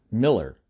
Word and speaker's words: Mueller: His last name is actually spelled like Mueller, as in Robert Mueller, but apparently he pronounced his last name as Miller.